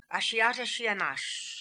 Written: Ashiata Shiemash

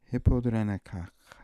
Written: hippodrenekakh

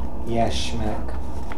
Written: yashmak

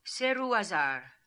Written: Serooazar

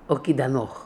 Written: Okidanokh

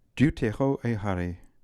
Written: deuteroehary

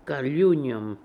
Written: Kaliunium

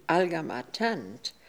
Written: Algamatant

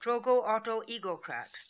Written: Trogoautoegocrat

